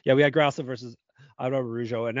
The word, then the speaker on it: Araújo: We're putting that extra syllable in there. Araújo.